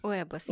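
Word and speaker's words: huevos: Уэвос. – Нет, яйца уэвос. Пишется первое, да, ачи, но читается уэвос.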